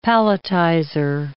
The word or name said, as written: palletizer